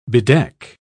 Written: bedeck

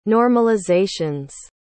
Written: normalizations

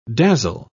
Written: dazzle

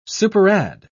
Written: superadd

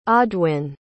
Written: audwin

